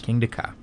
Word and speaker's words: Kingda Ka: And that's spelled K-I-N-G-D-A? – K-I-N-G-D-A-K-A. – Two words. – King de Ka.